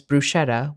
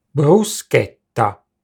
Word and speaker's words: bruschetta: Because some English people pronounce bruschetta as bruschetta with an SH sound, we tend to have a difficult time remembering how to pronounce it correctly in Italian. The correct pronunciation is bruschetta.